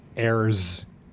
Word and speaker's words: heirs: Heirs. You don't pronounce the H. – No. – I apologize. – You don't pronounce the H. – Unless you're British.